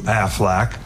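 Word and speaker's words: Aflac: Now they want a human man, you, Affleck, which rhymes with... – Affleck!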